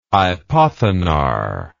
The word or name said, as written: hypothenar